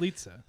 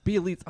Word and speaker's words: Bjelica: How do you even say his name? – Bjelica. – Bjelica. Oh, my gosh. I knew that, too.